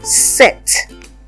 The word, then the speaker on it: sept: 7 is sept. Sept. The P in 7 is not pronounced in spelling sept.